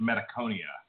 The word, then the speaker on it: Metachthonia: The CH is almost like a K sound. If you actually, I just looked it up. It's like, phonetically, it's Metachthonia.